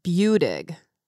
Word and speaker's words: Buttig: I mean, you're still into yoga, but that seemed like that was like Catherine Buttig yoga. – Yes. – Is that how you pronounce your name, by the way? – Very close. Buttig. – Buttig. – Buttig. It's a long U and then dig.